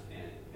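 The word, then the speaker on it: Alnwick: It doesn't look like it's pronounced Annick Castle, but that's the way we're told it's pronounced.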